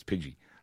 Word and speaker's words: Pidgey: I always keep going Pidgey, and everyone's like, no, you're saying it wrong. It's Pidgey.